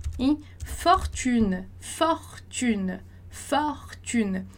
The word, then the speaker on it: fortune: Lembra lá da aula anterior do O, do som do O que a gente teve, quando eu falei que quando tem duas consoantes, o O anterior vai ser pronunciado com o som de O. Então, a gente fala fortune, tá? Não vai falar fortune, igual no português, fortuna, né?